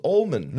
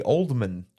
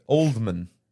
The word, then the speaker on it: Oldman: If it has a D, you gotta say it like that. – Oldman. – If it was Ullman, you could say it that way. But if it was with a D.